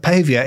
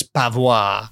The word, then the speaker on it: paviour: So as a glazier is to windows, a pavia is to paving. – Oh! – Yeah! – Very nice. That is good. – But it's spelt P-A-V-I-O-U-R. – Oh. – So I was reading it as pavois.